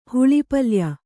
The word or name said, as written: ಹುಳಿಪಲ್ಯ